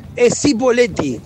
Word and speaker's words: Cipolletti: Es Cipolletti, es Cipolletti. Muchos le dicen Cipolletti.